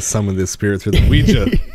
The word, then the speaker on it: Ouija: It's pronounced Ouija, not Ouija. It comes from the French and German words for yes. Oui and ja.